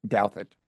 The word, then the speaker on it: Douthat: I'm starting to read your friend, everyone's your friend, Ross Doudat's, Doudat. – And I continue to insist that it's pronounced Douthat.